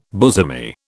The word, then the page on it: bosomy: British English